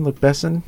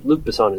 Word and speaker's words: Luc Besson: Luc, Luc Besson. Luc Besson. – I, Luc Besson is right.